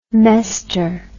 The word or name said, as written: mester